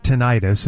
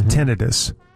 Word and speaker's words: tinnitus: That's how you pronounce it, not tinnitus. It's tinnitus.